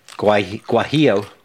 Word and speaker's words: guajillo: Chipotle chili, zingy lime, red bell peppers, guajillo.